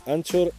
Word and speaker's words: Anchor: Anchor FM que se pronuncia Anchor en teoría.